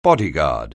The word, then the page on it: bodyguard: anglais